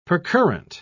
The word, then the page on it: percurrent: British English